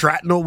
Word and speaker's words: shrapnel: Well, I've caught a lot of scrapnel recently. – Scrapnel? – Shrapnel, whatever the word is. It's early. How do you say it? Is it shrapnel? – Shrapnel.